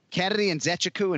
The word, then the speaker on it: Nzechukwu: So I walked up to the athlete, and I'm like, bro, Kennedy Nzechukwu? And he's like, perfect. I'm like, all right, then that's what we're going with.